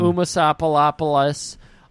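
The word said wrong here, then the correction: Umslopogaas: I am really sorry for fucking that name up, but it is hard with an English tongue to pronounce it, and they do not. – Oh, really? It's Umslopogos. – I don't think they ever they say it in the movie. – Yeah, it's Umslopogos.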